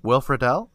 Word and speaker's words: Will Friedle: Is that actually how you pronounce his name? Because I've never heard it out loud. – Will Friedle? Yeah. – Yeah. – Yeah. – That's it? – Yeah, I'm like 99% sure that's it, yes.